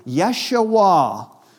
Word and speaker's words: Yeshua: Now, Jesus is our English pronunciation. The Hebrew pronunciation would have been closer to Yeshua, which means Yahweh saves.